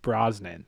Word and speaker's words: Brosnan: She got Bronson. She got Pierce Bronson. How do you say that? Brosnan. That's it.